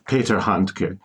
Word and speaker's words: Handke: How do you pronounce a uh, Hanke? – Hanke. – Hanke. Uh, Peter, so, Peter Handke, yes, um, Austrian poet mm-hmm.